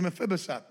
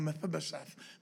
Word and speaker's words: Mephibosheth: It's a tongue tire, but it is Mephibosheth. That's the name. That's how you pronounce it. Mephibosheth. That's the proper enunciation.